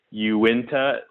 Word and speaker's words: Uinta: so that you said Uinta, like, and pronounced the U.